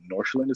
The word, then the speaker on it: Nordsjælland: I do know, though, that because of their, I guess their wider organization's relationship with like FC Nordsjælland is how you pronounce it,